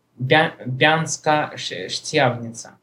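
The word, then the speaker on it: Banská Štiavnica: Банка Штемница, ну, она маленький. Она пишется как это Бянска-Штявница. Да, вот так вот.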